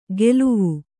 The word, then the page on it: ಗೆಲುವು: geluvu; Kannada